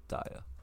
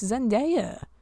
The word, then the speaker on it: Zendaya: Please don't tell me you actually pronounce it Daya. – So I said it right. – No. – No, you don't. It's Zendaya.